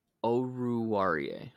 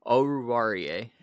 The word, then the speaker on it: Oruwariye: Is it Owuzie? Is that how you say his name? – Oruwariye. – Okay. I'll get it right next time. Oruwariye.